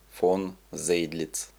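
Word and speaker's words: von Zedlitz: Оно является вольным переводом с немецкого языка стихотворения австрийского писателя-романтика Иосифа Христиана фон Цедлица. Иначе его имя произносят Иосиф Кристиан фон Зейдлиц.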